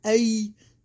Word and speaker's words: Ai: After that, Abram traveled south and set up camp in the hill country with Bethel to the west and Ai to the east. – Okay. – I want to say it just like that. It's spelled A-I-I.